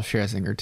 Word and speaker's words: Scherzinger: So we're talking about Nicole Scherzinger today.